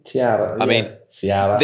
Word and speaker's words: Tsiaras: I hope I pronounced that right. Tsiaras? – Tsiaras.